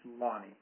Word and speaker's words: Lani: His wife's name is spelled L-A-N-I. – Lonnie, yeah. – It's Lonnie. – Right. – I'm sorry, I mispronounce it all the time. I should never have seen it written. I'd be just fine. But Lonnie.